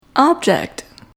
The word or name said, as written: object